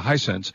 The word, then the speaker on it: Hisense: Hisense? – Yes. – Yeah, Hisense, 65H9F.